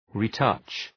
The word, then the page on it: retouch: ri:’tʌtʃ